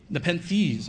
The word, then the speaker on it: Nepenthes: One's called Nepenthes, and I'm probably mispronouncing that because it's some, Nepenthes, okay, thank you.